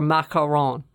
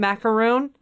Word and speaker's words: macaron: I watched it really for macaron. I wanted to know. I was like, is it macaroon? What's the macaroon? Macaron. Am I saying it wrong? I wasn't. It's macaron.